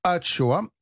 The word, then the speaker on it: axoa: Ah euh, alors, euh, ça s'écrit a ça se prononce Achoa, et ça signifie haché en basque parce qu'il s'agit donc mmh.